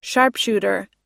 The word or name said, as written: sharpshooter